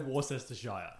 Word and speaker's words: Worcestershire: No, I say Worcestershire.